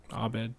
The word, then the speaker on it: Abed: Is it, what's the guy's name? Abed? I'm not saying that right. – Abed. – Abed.